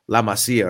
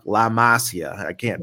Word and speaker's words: La Masia: And this is going to go to my last point for our conversation today is La Masia, or as I've been seeing on TikTok, La Masia. That drives me crazy when people can't pronounce it correctly. La Masia just producing yet more gems.